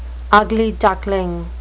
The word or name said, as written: ugly duckling